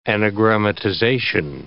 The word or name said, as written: anagrammatization